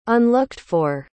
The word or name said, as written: unlooked-for